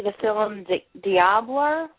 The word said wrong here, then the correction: Dalibor: How do you say that correctly? That short film you did. – You mean the last one I did? I'm actually thinking about changing the name because I find that a lot of people find it difficult to pronounce. They're not sure what it is. So, but yeah, it's called Dalibor. But okay.